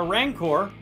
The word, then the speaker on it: Rancor: So when you have to fight a Rancor, did I say that right, Nikki? – Yeah, you did.